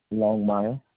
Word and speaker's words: Longmire: Okay, your first name is spelled Antoine, A-N-T-O-I-N-N-E. – Correct. – Last name, is it Longmire? – Longmire. – Longmire, L-O-N-G-M-I-R-E.